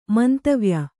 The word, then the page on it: ಮಂತವ್ಯ: mantavya